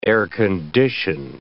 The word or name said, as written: air-condition